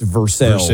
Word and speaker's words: Versailles: It's like Versailles being pronounced Versailles in Kentucky.